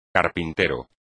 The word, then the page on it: carpintero: espagnol